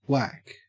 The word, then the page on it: wack: Australian English